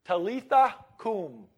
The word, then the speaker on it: Talitha kum: He took her by the hand and said to her, "Talitha kum," is how I pronounce that, which means, "Little girl, I say to you, get up."